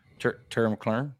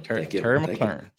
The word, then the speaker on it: Terry McLaurin: Can you say his name right, though? – Terry McLaurin. – There you go. – Terry McLaurin. – Thank you.